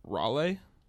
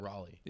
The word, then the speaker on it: Raleigh: So like my deck choice was poor for our regional, but like Rugscape Shift took second at Raleigh. I just butchered that. – Raleigh. Yeah, it's just Raleigh.